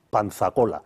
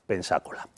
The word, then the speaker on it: Pensacola: Y el nombre original en español pues es Movila, como Pensacola, que al principio los españoles le llaman Panzacola, pero los ingleses deforman para su pronunciación el nombre, que acaba siendo Pensacola.